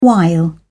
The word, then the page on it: while: British English